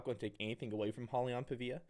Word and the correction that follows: Paiva: Paiva, excuse me.